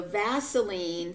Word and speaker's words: Vaseline: So Vaseline, think about it, it rhymes with gasoline.